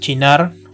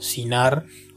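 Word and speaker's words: Cynar: que es chinar o sinar, como quieran pronunciarlo.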